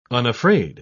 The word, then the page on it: unafraid: British English